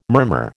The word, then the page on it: murmur: American English